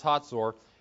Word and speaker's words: Hazor: Yes, it's pronounced Hazor.